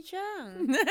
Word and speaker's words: Zhang: Hey everyone, this is Moni Zhang.